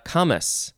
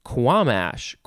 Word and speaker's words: camas: Next is camas, C-A-M-A-S, or two S's, or, oh, interesting, you could spell it and pronounce it Quamish, with a Q-U-A-M-A-S-H. So camas or Quamish.